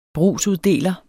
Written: brugsuddeler

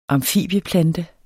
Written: amfibieplante

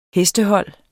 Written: hestehold

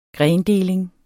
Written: grendeling

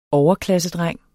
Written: overklassedreng

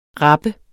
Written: rapper

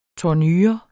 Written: tournure

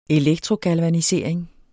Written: galvanisering

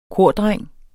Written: KORDRENG

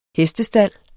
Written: hestestald

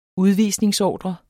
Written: udvisningsordre

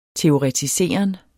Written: teoretiseren

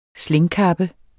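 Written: SLÆNGKAPPES

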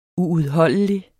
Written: uudholdelig

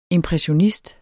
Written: impressionist

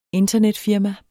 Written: internetfirma

